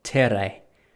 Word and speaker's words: Terre: and it's called Terre. It's spelled T-E-R-R-E. Men's, so I have the spray there.